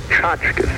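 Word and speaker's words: tchotchke: Now, the word you're looking for is tchotchkas.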